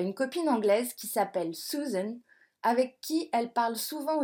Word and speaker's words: Susan: Susan, in English, se prononce Susan, et pas Suzanne, comme le prénom français.